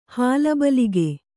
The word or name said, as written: ಹಾಲಬಲಿಗೆ